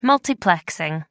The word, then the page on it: multiplexing: American English